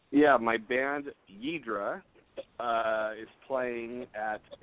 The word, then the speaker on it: Yidhra: And a lot of people ask me, like, they hear the band name, Yidra, which is spelled Y-I-D-H-R-A.